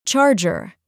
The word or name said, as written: charger